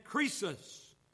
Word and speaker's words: Croesus: The king of Lydia, when Cyrus of Persia conquered it, was a man named Croesus. That's spelled C-R-O-E-S-U-S, Croesus.